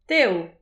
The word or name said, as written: tev